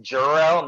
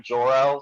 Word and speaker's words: Jor-El: Although it's pronounced Jor-El, not Jor-El, so.